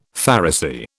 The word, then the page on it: Pharisee: British English